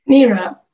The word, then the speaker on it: Nira: Nira.